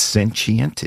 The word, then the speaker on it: sentient: It's not sentient, it's sentient. Is that the accurate pronunciation? – Sentient.